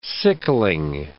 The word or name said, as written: sickling